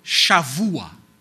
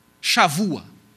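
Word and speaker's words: Shavua (שבוע): E aqui nós precisamos entender um conceito que é o conceito de Shavua. Shavua. Está um B aqui, porque em hebraico B, dependendo da posição dele na palavra, ele tem som de V. Então você escreve B, mas aqui pronuncia V.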